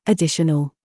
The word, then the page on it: DAD: British English